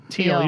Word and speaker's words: Thiel: And Peter Thiel, who will be... – I believe it's pronounced Thiel. – Thiel, you're right. Yeah. It's Thiel.